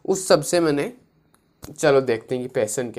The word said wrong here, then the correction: passion: क्या अबे ये पैसन बोलते कैसे प्रोनाउंसिएशन क्या इसका पैशन पैशन वापस पैशन passion passion passion passion passion okay passion जो